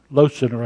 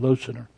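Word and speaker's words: Loesser: There's a fellow by the name of Frank Losener. Losener, I believe is how you pronounce his name, Losener.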